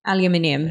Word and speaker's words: aluminium: Say aluminum. – Aluminium. – There it is. – Ooh. See, I knew that was different.